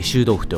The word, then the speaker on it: 臭豆腐: 臭いに豆腐と書いて臭豆腐と読む